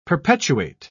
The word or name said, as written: perpetuate